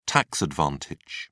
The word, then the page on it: tax advantage: British English